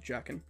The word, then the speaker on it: Jaken: How do you spell it? – J-A-K-E-N. – J-A-K-E-N? – Yep. – E-N. – He's like this little goblin. – That's how to pronounce it, Jacken.